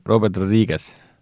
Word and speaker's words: Robert Rodriguez: siis see peaks niimoodi käima, et Robert Rodriguez, mitte, ma vanasti ütlesin alati Robert, Robert Rodriguez,